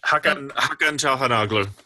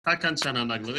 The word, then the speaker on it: Hakan Çalhanoğlu: It really ended up being, what, how did you say it? Çalhanoğlu? I don't know how to even say his name. – Yeah, but Hakan Çalhanoğlu. – Hakan Çalhanoğlu.